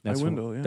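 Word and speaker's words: Aiwendil: Then there's Iwendil. I think I said that right. – Yeah, I think you did. Iwendil.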